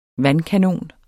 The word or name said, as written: vandkanon